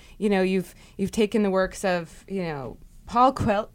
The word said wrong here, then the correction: Paulo Coelho: how do you say Paulo Coelho,